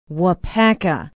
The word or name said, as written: waupaca